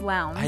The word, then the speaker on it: Lowndes: But I'd seen her name before, but to be honest with you, I thought it was Jessica Lowndes or something. – I had to convince him it was Lowndes.